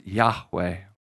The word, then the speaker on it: Yahweh: We pronounce those Hebrew letters Yahweh.